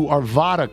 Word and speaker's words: Arvada: As a resident adjacent to Arvada, Colorado, let me say the second A is pronounced like it is in hat.